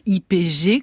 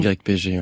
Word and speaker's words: YPG: IPG », comment vous prononcez-vous ? – YPG.